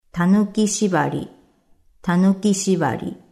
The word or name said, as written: Tanuki shibari